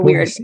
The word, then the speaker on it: Boise: And one thing that's funny is I've been corrected so many times on how I pronounce the city because I was saying Boise. And that's not how you say it. It's Boise with like a soft S and not as, doesn't that blow your mind?